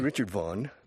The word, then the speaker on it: Richard Vaughan: En inglés, pues sí, Richard Vaughan, pero en inglés se dice Colgate, no Colgate.